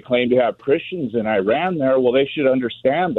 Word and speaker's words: Iran: And it was a reporter from Iran criticizing an American soccer player for saying Iran. – You're pronouncing our country's name wrong. Our country is named Iran, not Iran.